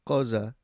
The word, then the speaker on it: Xhosa: Xhosa. – Xhosa. – Xhosa. It's a click, so like most people can't pronounce it anyway.